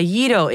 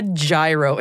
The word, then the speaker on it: gyro: Now, usually, when I'm talking to people about a gyro issue, it's actually a Yido issue. You know, the Greek cousin of a doner kebab, the pita wrap a lot of people mispronounce. But no, today it's actually properly a gyro issue, as in the gyro on the Hubble telescope.